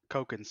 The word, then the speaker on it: Cogan: is it Kogan? – Kogan syndrome. – Yeah.